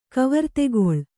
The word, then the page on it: ಕವರ್ತೆಗೊಳ್: kavartegoḷ